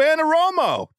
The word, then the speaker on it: Anarumo: It's Big Lou Anarumo.